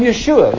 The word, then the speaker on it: Yeshua: When you met him, you say, I'm Yeshua. That's how you say it in Hebrew. I'm Yeshua, the son of Mary.